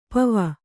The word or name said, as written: ಪೂವು